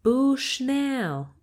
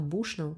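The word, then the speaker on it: Bushnell: Почему-то ее произносили как Кэндис Бушнелл. Мне всегда казалось, что она бушнул, не знаю.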